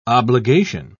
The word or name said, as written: obligation